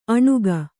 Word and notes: ಅಣುಗ: aṇuga

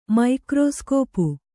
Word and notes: ಮೈಕ್ರೋಸ್ಕೋಪು: maikrōscōpu

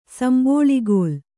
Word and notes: ಸಂಬಳಿಗೋಲು: sambaḷigōlu

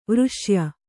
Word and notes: ವರ್ಷೀಯ: varṣīya; Kannada

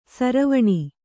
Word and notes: ಸಾರವಣೆ: sāravaṇe

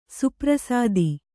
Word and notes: ಸುಪ್ರಸಾದಿ: suprasādi

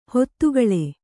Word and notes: ಹಿತಗೊಳ್: Kannada

